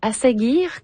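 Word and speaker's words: Ásgeir: Moi, je découvre cet artiste Asagir, qu'on prononce.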